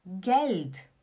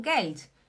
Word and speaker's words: Geld: E dinheiro você fala Geld. Geld. Atenção, você não fala Geld. É como se tivesse um T. Um T no final. Você fala Geld. Geld. Geld. Né? Dinheiro, Geld. Hast du Geld?